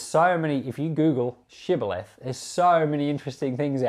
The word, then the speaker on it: Shibboleth: And then they would say, "Pronounce Shibboleth." And apparently they couldn't pronounce their SHs. So they could only say Sibboleth.